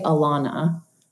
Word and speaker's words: Ilana: And they both said, oh, it's Ilana, like a soft I. And so now there are three potential interpretations kind of floating out there. So I say Ilana, but I'm really fine with any pronunciation of my name. And I'm sort of charmed by the fact that it doesn't have a stable definition or sound.